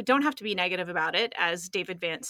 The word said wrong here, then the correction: Van Zandt: said, I can't say his name, David Van Zandt.